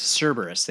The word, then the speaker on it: Cerberus: We thought it was Cerberus. – Cerberus. There you go. Yeah, that's better pronunciation.